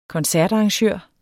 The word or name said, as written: koncertarrangør